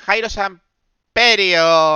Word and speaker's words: Jairo Samperio: Jairo Samperio. Lo he dicho bien, vamos. Bueno, Jairo, 2022. Para mí. – Ha, eh, tardado toda la ha la temporada, pero acaba de decir bien el nombre.